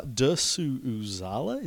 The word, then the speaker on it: Dersu Uzala: but uh, Dersu Uzala, is that right? – Yes.